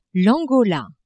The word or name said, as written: Angola